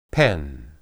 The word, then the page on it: pen: Hebrew